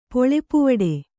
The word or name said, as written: ಪೊಳೆಪುವಡೆ